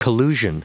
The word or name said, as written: collusion